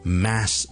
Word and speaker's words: mass: mas 仲有一个 a 音嚟读噶，咁但系正确嘅发音咧系一个 a 音嘅 a，即系我哋好似读 fat f a t fat，或 cat c a t cat a fat cat a，咁咧挤落去 mass 呢个字度咧就系读成 mass。